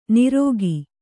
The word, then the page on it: ನಿರೋಗಿ: nirōgi